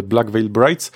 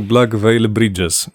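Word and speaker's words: Black Veil Brides: Brights, e, już pomijając akcentowanie, ale Black Veil Brights z czego, możesz powiedzieć, jak Daniel to przeczytał? Daniel przeczytał Black Veil Bridges.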